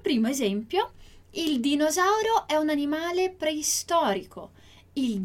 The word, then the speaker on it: dinosauro: No Brasil, em algumas regiões, esse som do D, como dinosauro, ele é utilizado, mas em grande parte a gente não vai pronunciar a letra D quando, né, ela vem antes da letra I.